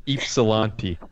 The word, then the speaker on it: Ypsilanti: which letter is silent? I'll say it's Silanti. I'll say it's Silanti. – I want to go with Ypsilanti. – Say Ypsilanti. – Yeah, everything is perfect. If we know anything about places that sound like they're in Russia, is that you pronounce every single word. Or every single letter, sorry.